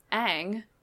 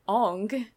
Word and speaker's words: Aang: So, like, he changed the pronunciation of people's names. So, Aang is now Ong,